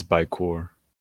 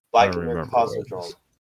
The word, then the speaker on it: Baikonur Cosmodrome: I think it's Baikor. Sounds bad. – Baikonur Cosmodrome. – Yep, that's close enough. – Baikonur Cosmodrome. That's how you pronounce it, apparently.